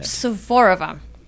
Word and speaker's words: Suvorova: It's a very Russian last name, but it's love it. Suvorova.